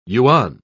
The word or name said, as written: yuan